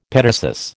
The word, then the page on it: petasus: American English